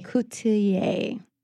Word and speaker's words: Coutelier: Is that how you pronounce Coutelier. – It? Coutelier. – We get a million different ways.